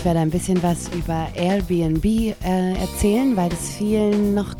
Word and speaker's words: Airbnb: Airbnb, genau. Und zwar das heißt, das R spricht man Deutsch und die Bs spricht man amerikanisches Englisch.